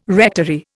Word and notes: rectory: Indian

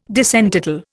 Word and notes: disentitle: Indian

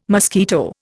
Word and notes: mosquito: Indian

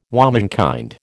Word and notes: womenkind: American English

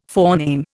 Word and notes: forename: Indian

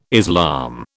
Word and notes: Islam: British English

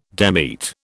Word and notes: demit: British English

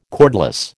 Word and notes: cordless: American English

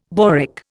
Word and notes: boric: Indian

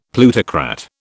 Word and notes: plutocrat: British English

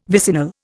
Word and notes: vicinal: Indian

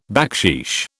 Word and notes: backsheesh: British English

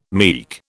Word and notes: meek: British English

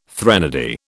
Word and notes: threnody: British English

